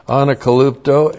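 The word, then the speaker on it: Anakalupto (ἀνακαλύπτω): Anakalupto, A-N-A-K-A-L-U-P-T, long O.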